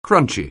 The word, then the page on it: crunchy: anglais